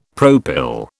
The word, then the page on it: propyl: British English